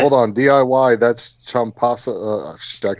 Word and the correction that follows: Ciampa: Gargano and Ciampa, yeah.